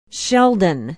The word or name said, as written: sheldon